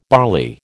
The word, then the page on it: barley: American English